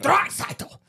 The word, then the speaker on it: Draisaitl: I was just saying Draisaitl. – Yep, that's got it. – The normal way to say it. – Yeah, that's how you say his name.